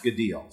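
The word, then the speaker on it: gadil (גדיל): The Hebrew word is spelled gadil, or G-A-D-I-L, and it's pronounced gadil. So G-A-D-I-L, pronounced gadil.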